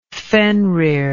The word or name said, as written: fenrir